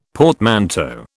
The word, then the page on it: portmanteau: British English